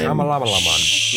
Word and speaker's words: Shyamalan: Night Shyamalan. I don't know how to say his last name. – Shyamalan.